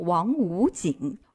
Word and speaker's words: 王府井: 再之后呢，就是静涵说的这个吞音了，公主坟要叫公乳坟儿，王府井呢要念成王五井。